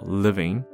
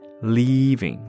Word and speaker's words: leaving: 但无论如何 ,loud leaving 都听起来是一个很有趣的趋势，大家可以多多注意一下 ,loud leaving, 也注意一下发音，不是住 living, 而是离开 leaving 拉长的 loud leaving loud leaving。